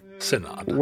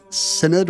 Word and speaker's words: synod: It's definitely not the long I. I think synod. – Webster's prefers synod with the schwa.